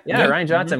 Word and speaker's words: Rian Johnson: Rian Johnson? – Yeah. – Rian Johnson.